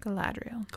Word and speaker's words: Galadriel: Is that how you say it? – Galadriel. – Galadriel.